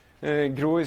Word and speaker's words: grå: Grå is the Norwegian word, for gray. Also, it's pronounced like org, except the other way.